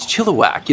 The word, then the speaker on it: Chilliwack: I remember they even kind of clarified this, you know, from this little town in Canada named Chilliwack.